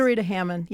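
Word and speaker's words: Zaretta Hammond: Zarita Hamanda. Is that how you pronounce it? – Zarita Hammond, yeah. – Hammond.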